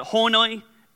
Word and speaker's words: Honi: His name was, I don't even really know how you pronounce it. I pronounce it Honi, H-O-N-I.